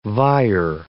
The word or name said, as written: vier